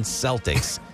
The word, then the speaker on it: Celtic: Make sure you pronounce it right, too. Not the Boston Celtics. Right. It's Celtic.